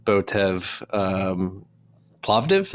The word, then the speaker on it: Botev Plovdiv: Botev Plovdiv, is that how you pronounce it? – Yes, exactly.